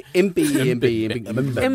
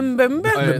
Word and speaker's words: Mbembe: Man har ligesom set, nu kan jeg ikke lige huske, den hedder Moleke Bembe. Altså den der. Jeg har nok udtalt det forkert. – Tror du Mbembe. – Mbembe.